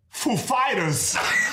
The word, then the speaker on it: Foo Fighters: And he comes up and he's like, he said, he asked us if the accent was on Foo or Fighters. And we know who he is. Like, of course, we know how he speaks. And we said, the accent is on Fighters, actually.